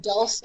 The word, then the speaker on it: Dulce: So in my trilogy, my third book, Aurora's Curtain, I am, my character goes down to, Dulce, is that how you say it? Dulce? Dulce? How do you pronounce the basin? – Dulce.